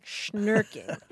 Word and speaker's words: shnerking: S-H-N-E-R-K-I-N-G. Rhymes with twerking. Snurking.